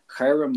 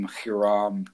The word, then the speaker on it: Hiram: But the reason why it's Hiram is because it's not spelled with an H in Hebrew. It's actually the letter chet, which is a ch sound, which we don't really have uh, in English. Uh, but I will just say Hiram from now on, and then we'll explain kind of what that means later.